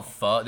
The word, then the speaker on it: pho: It's spelled pho, like pho. – Like pho, yeah. – But it's called pho.